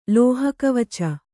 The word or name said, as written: ಲೋಹಕವಚ